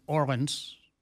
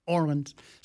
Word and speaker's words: Orleans: Orleans in the background, or for those locals, will know it should be pronounced Orleans. There you go, Orleans.